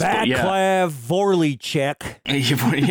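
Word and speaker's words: Václav Vorlíček: Yeah, it's an interesting one, because Vaclav Volchek, or I'm butchering all the names. Any names here will be butchered. Apologies. – Vaclav Volchek. – Yeah, there we go. That's close enough.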